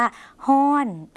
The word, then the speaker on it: ฮ้อน: ฮ้อน